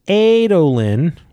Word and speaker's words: Adolin: First, Adolin is pronounced Adolin, which we understood.